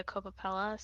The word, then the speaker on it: Coppola: Is it Coppola? Yeah. It's oh, like it's like Francis well. Ford Coppola.